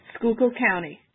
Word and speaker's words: Schuylkill: Schuylkill County.